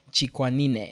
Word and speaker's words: Chikwanine: Yeah, so my name is Michel Chikwanine, and it's pronounced chick, like a little baby chicken. – Chick. – Wah, like a baby crying. – Wah. – Knee. The knee that we all have, and then ne, the sound the horse makes.